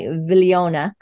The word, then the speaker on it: Viglione: She co authored the book with Dr. Deborah I'm going to say try to say it correctly, Viglione, but the proper Italian is more closely something like Vigliona, and I, Villi, I, I, can't do it.